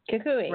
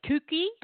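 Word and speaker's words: Cucuy: And then it's C-U-C-U-Y. – Kukui. – Right? How do you say that? – C-U-Y. – Kukui. – L-Kukui. – Kukui. – It is Kukui. I was like, kookie? Kookie? El kookie.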